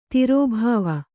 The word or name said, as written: ದುರ್ಭಾವ